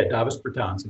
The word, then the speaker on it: Davis Bertans: Davis Bertans? – Yeah, Davis Bertans.